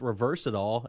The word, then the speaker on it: resveratrol: I think it's actually pronounced reverse it all, and that's sort of a funny name if it doesn't work.